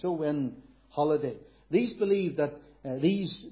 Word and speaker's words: Samhain: It's spelt Samhain, but that's not how it's pronounced. It's pronounced Sowain, S-O-W-I-N, because in the Gaelic words apparently are pronounced differently.